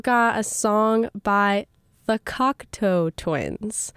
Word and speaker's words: Cocteau Twins: And the reason I'm playing them is because last week I played them for the first time, but the entire show I was pronouncing it Cockatoo. Which is really wrong. It's Cocteau. I was pronouncing it like the bird. I just didn't read it carefully enough, I guess.